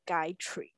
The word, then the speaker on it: Gayatri: 然后那时候他就是教我怎么念的时候，他就说，你就想象就是 Guy，然后跟 Tree 就是 Gayatri 这样。